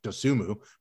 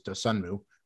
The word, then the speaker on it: Dosunmu: And again, I'd like to thank the Bulls for telling me it's pronounced Dosunmu. Before they drafted him, I thought it was Dosunmu, which shows you how much attention I pay to college basketball.